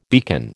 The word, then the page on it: beacon: American English